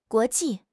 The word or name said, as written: guo2 ji4